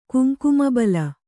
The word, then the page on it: ಕುಂಕುಮಬಲ: Kannada